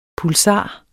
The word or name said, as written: pulsar